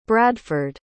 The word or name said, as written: bradford